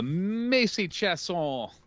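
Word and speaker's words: Macy Chiasson: Macy Chasson, that is how you say it. I'm not being pretentious.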